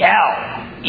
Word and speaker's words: El: If it were singular, it would be El, E-L. If it were dual, meaning two gods, it would be Elah, E-L-A-H. But instead it's Elohim, E-L-O-H-I-M.